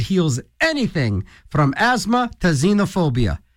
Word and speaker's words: xenophobia: The xenophobia is spelled with an X. But needless to say, it's still pronounced xenophobia.